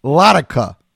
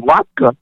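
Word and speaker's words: latke: And then you rhymed Hanukkah with Latika. – Right. Yes, yes. So people ask me all the time, like, what's that word latika? And I said, no, latka.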